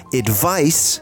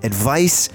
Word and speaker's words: advice: Advice, and pay close attention to the pronunciation, advice is a noun.